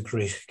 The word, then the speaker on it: Gwrych: It's Escape to Gwrych Castle, a Jewish refugee story.